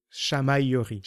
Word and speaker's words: chamaillerie: Ah, ok, toujours le double L qui bloque un petit peu. C'est le double L en français, c'est un peu comme le U. Chamaillerie. – Chamaillerie. – Chamaillerie. Voilà, chamaillerie. On prononce rapidement.